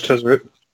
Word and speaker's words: Tezzeret: I see on the post you had Tezzeret. Is that how you pronounce his name? – Exactly, yes. – Yeah, that was Tezzeret.